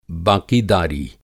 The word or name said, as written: बाक़ीदारी